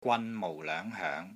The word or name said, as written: GWAN3 MOU4 LOENG5 HOENG2